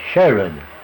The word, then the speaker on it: Sherode: How's it spelled? S-H-E-R-O-D-E. That's Sherrod.